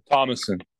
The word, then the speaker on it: Thomasson: Thomasson.